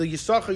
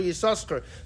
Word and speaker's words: Yisachar: So too, the Radak says, and the Ibn Ezra, they both link the Yisachar, Yisachar, to that pasuk in Divrei Hayomim. You only say one.